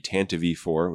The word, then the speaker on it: Tantive IV: We've had the Tantive IV, which is the correct way to pronounce it.